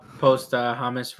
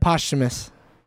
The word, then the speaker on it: posthumous: It says on a wiki too, post Hamas release. How do you say that word? – Posthumous.